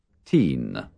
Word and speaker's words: teen: Teen. – La N final debe recalcarse al pronunciarla. También se distingue bien en la palabra teenager.